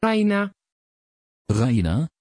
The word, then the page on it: rayna: Dutch